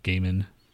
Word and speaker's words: Gaiman: He will appear as Satan in Good Omens, Amazon, and the BBC's adaptation of Gilman and Terry Pratchett's fantasy novel. – Gaiman. – Were you saying that just to be funny, or did I say it wrong? – That's his name, Neil Gaiman.